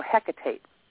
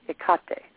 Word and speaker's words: Hecate: I said, beg your pardon, because I had always heard it pronounced, you know, Hecate. And she said, no, Ekate.